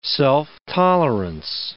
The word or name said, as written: self-tolerance